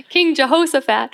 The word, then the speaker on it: Jehoshaphat: Well, I immediately think about the story in 2 Chronicles, I believe, with King Jehoshaphat. Am I saying that right, Pastor Joe? Okay. – Sounds right to me. – King Jehoshaphat.